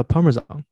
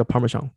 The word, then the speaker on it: Parmesan: 那我再给你说一个，嗯，那下面我说的这个奶酪呢，也源自意大利，它叫 Parmesan。有些地方叫 Parmesan，然后我查过，其实这两个发音都可以。